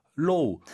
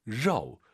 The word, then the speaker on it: 肉: 로우, 로우, 이상하죠? – 로우. – 일단 R 발음은 러, 이 발음이 들려야 되고요. 오유는 어우예요. 러우, 러우, 네.